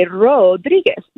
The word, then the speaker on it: Rodriguez: So the last name of Ro is R-O, and Rodriguez, D-R, is the blend, the dir. So you say Rodriguez. You don't have to roll your R's. I know our rolling R's are hard. – I can roll the R's. R-R-R-R-R-R.